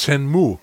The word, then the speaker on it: Shenmue: Shen-Menu, dieses japanische Ding, was ich nicht kenne. Du meinst Shenmue? Shenmue, genau.